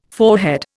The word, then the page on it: forehead: Indian